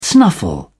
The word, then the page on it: snuffle: English; British English